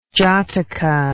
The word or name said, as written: jataka